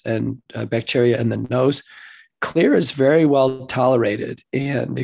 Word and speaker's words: Xlear: Now, it's spelled X-L-E-A-R, so people know X-L-E-R.